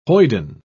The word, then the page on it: hoyden: British English